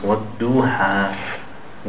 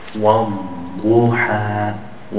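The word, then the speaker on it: Wal duha (والضحى): What duha? What duha? That's not correct. The correct way, it should come from the back and upper palate. Wal duha. Wal duha.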